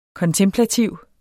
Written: kontemplativ

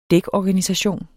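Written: dækorganisation

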